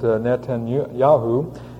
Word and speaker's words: Netanyahu: but Netanyahu.